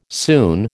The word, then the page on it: soon: American English